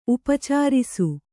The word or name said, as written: ಉಪಚರಿಸು